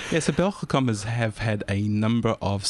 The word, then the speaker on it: Belgacom: Belgacom. – It's very, very good accent there.